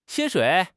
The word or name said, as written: 薪水